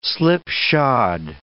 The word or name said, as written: slipshod